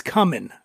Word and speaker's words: cumin: So we're going to put some cumin on a pretzel and get yourself a cumin pretzel. – I think it's cumin. I think you're mispronouncing it.